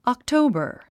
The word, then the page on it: October: ɑktóubər; オクトウバー